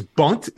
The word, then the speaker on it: Bunt: Punt of Burial Squad. I think it's his punt. I always get stuck on this. It is. It's bunt. It's a B.